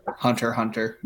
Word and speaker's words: Hunter x Hunter: It's just Hunter Hunter, by the way. – If you say the X, it doesn't matter, but most people don't pronounce it with the X.